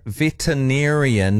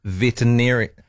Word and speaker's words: veterinarian: It's veterinarian, not veterinarian.